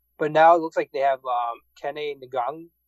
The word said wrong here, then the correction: Nwangwu: I can't pronounce his Nwangwu. That's right. I think I got that right.